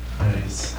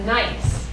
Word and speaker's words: gneiss: It's spelled G-N-E-I-S-S, and it's pronounced nice. – A nice. So you say, this is a nice rock, you know?